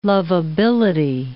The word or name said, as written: lovability